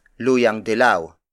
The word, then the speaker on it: luyang dilaw: Turmeric is also known as luyang dilaw.